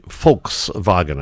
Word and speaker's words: Volkswagen: Actually, Volkswagen. I believe the V is pronounced F, or RF, and you can see it's the wagon.